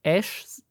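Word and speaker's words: Æ: X, wie spricht man dieses AE, das so zusammengeschrieben ist? Ja, also ist? Es, es, kann auch sein, dass man es als Ash ausspricht.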